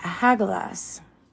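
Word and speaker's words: Hagalaz: And then we've got Hagalas. The phonetic value for Hagalas is H. Pronunciation Ha-ga-las.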